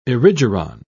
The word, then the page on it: erigeron: British English